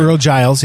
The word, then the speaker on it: Earl Giles: Earl Giles, yeah. – Thank you very much. Yeah. I see the gene, it throws me off. But yeah. It's a Giles. So Earl Giles.